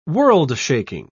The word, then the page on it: world-shaking: British English